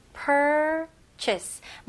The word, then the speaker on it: purchase: 그래서 r는 힘을 주셔야 됩니다. purchase 이런 식으로 하시면 안 되고 purchase. purchase. 마지막에 chase 이거를 chase라고 발음하시는 것보다는 chase. chase 약간 i 하고 e 소리예요. purchase.